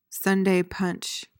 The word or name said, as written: sunday punch